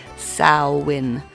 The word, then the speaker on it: Samhain: Spelled Samhain, but pronounced Samhain. There we go.